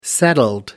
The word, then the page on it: settled: American English